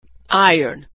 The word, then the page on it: iron: American English